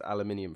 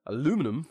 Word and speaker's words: aluminium: So they built a solid frame blimp with aluminium frames, because aluminium was this new metal. – Yeah. – Or for aluminum, for those who pronounce it that way.